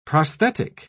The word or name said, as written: prosthetic